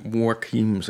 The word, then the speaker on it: Joaquin: Joaquin. – Joaquin. – Volgens mij moet je Joaquin zeggen.